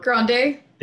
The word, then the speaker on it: Grande: Is it Ariana Grande? – There we go.